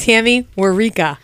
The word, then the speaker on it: Tammy Warika: And tonight's special guest is Tammy Warika.